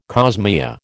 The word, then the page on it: cosmea: American English